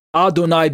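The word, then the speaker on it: Adonai (אדני): pronounced Adonai because of the different vowel markings underneath the little consonantal letters, and pronounced Adonai because the Masoretic verbal tradition says it's Adonai, even without the vowel markings, right?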